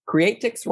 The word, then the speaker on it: Creatrix: Thus, your book, Creatrix Rising, am I pronouncing that right? – Creatrix, yes.